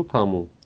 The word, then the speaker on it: тому: Так, я, два, я еще раз дважды скажу тому. Обратите внимание на ударение, как оно распределяется. Тому, тому. Ну-ка попробуйте. Тому. Тому. – Тому. – Угу, угу. То есть как будто это немножко два разных слога.